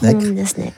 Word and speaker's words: Necker: Mais on prononce Necker. Voilà, ce que beaucoup de personnes ignorent.